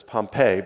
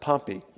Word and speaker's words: Pompey: Now, Pompey, some people will pronounce that as Pompeii, but Pompeii was actually the city that was destroyed by the volcano. The general's name is pronounced Pompey.